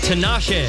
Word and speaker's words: Tinashe: Is it Tinashe? – Tinashe. Yes. Well, you know. – That's right. – Oh, yeah. – Actually, it's pronounced Tinashe. That's all right. It's close enough.